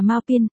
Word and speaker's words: Mao Phiến: Mao Phiến phát âm là Mao Pin, Mao Bình Nhạn phát âm là Mao Ping Yan.